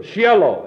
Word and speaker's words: Shiloh: And there's no I in it in the Hebrew. Shiloh. It's simply pronounced S-H-L-O, Shiloh, and it means in Hebrew, that which is His.